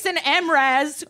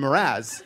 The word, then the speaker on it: Mraz: You know that it's Jason Mraz. – It's Jason Mr. Az, is what I thought it was. – You've seen Mr. Az in conference 12 times, I've seen him 12 times in concert.